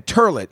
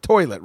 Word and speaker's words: toilet: I'm like, you know, you keep saying turlet. You understand it's pronounced toilet, right?